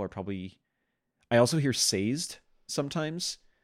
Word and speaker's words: Sazed: So I believe canonically in the audiobooks, which I believe are, according to Brandon, the canonical pronunciations, I believe it is Sazed.